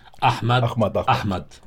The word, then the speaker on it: Ahmad: Ahmad.